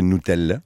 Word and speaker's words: Nutella: Oh, moi, j'aime ça m'adapter parce que si la vraie prononciation, c'est Nutella, j'aurais tendance maintenant à y penser tout le temps parce que tu me l'as appris.